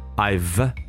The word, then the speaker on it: I've: It's important that you listen, hear, and actually say the V sound, I've, right?